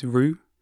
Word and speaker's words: Rhyw: So just to pick a couple of examples, one half of Cassegrain, I'm not very good at pronouncing his name, I think it's Rue, R-H-Y-W, released this record this year called Biggest Bully.